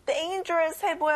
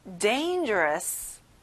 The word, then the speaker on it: dangerous: 위험한, dangerous. 자, 앞에 dane의 강세를 넣어보는 거예요. dangerous.